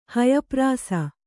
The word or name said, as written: ಹಯಪ್ರಾಸ